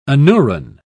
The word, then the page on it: anuran: British English